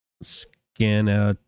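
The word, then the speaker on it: Skaneateles: They say, I live in the Finger Lakes and Scannatelles is pronounced Scannatelles. Scannatelles? I don't know. All the vowels are pronounced.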